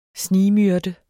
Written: snigmyrde

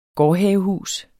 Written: gårdhavehus

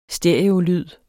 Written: stereolyd

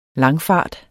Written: langfart

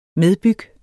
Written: medbyg

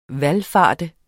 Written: VALFARTENDE

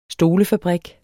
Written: stolefabrik

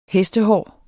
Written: hestehår

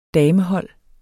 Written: damehold

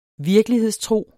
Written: virkelighedstro

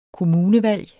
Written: kommunevalg

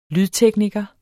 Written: lydtekniker

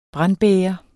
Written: brandbæger

